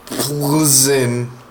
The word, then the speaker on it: Plzeň: It's from Plzeň. So it's spelled P-L-Z-E-N. – Oh, Plzeň. – Plzeň, Czech.